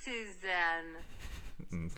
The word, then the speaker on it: Cézanne: Der gute Emil, also unsere Hauptperson, Solar, hat jedoch neben Cézanne, der folgendermaßen Paul ausgesprochen Cézanne. Wird. Mach nochmal. – Paul Cézanne. – Klingt perfekt. Cézanne.